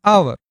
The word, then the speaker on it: hour: Some words begin with H. But if H is silent, such words take an. Say the phrase an hour.